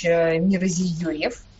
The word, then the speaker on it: Мирзиёев: Новоизбранный, вновь избранный президент Узбекистана Шавкат Миромонович Мирзиёев. Везде так «ё» стоит с двумя точечками, чтобы правильно было. – Так правильно произносится его фамилия по-узбекски, и поэтому это очень точная русская транскрипция его имени, отчества и фамилии.